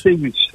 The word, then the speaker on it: Céus: Au nom de Patrick Céus. – Alors, perdu passeport Patrick Céus. – Céus, oui, c'est ça, oui. – Patrick Céus, comme ça se prononce, hein, Céus. – C-E-U-S, en quatre lettres, exactement.